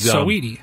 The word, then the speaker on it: Saweetie: Can anyone tell me about, I don't know if it's pronounced Saweetie or Sweetie. She was Saweetie. – Saweetie.